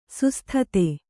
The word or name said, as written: ಸುಸ್ಥತೆ